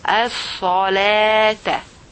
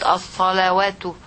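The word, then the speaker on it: الصلاة: Here, look above the waw of the word as-salah. It has like an alif above, so you pronounce it alif. So it will be as-salata, not as-salawatu, not waw, as-salata.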